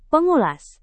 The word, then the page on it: pengulas: indonesian